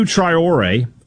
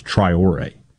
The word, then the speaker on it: Traoré: But Seydou Traoré, I want to thank my love of the English Premier League for letting me know how to pronounce Traoré. You look at that name, you're like, where do you get Traoré out of that? I promise you that's how you pronounce it.